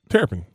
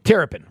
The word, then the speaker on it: Terrapin: How do you pronounce it? – Terrapin. – Terrapin, right? Mm-hmm.